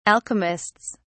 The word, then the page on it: alchemists: British English